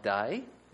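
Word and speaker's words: day (δεῖ): It sounds like day is today. Tomorrow is another day. It's spelled a bit differently, D-E-I.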